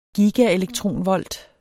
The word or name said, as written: gigaelektronvolt